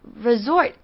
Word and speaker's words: resort: 好，我们再来看 resort 这个字。 resort 这个里面的 S 呢，是发 Z 的音 ,resort。